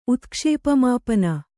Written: ಉತ್ಕ್ಷೇಪಮಾಪನ

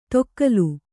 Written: ತೊಕ್ಕಲು

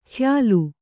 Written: ಖಯಾಲು

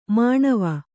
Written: ಮನವೆ

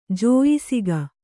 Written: ಜೋಯಿಸಿಗ

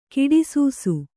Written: ಕಡುಸಾಸ

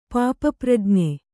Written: ಪಾಪಪ್ರಜ್ಞೆ

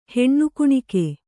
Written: ಹೆಣ್ಣುಕುಣಿಕೆ

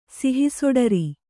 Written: ಸಿಹಿಸೊಡರಿ

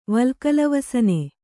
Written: ವಲ್ಕಲವಸನೆ

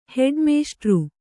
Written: ಹೆಡ್ಮಾಸ್ಟ್ರು

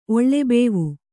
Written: ಒಳ್ಳೆಬೇವು

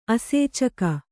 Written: ಅಸೇಚಕ

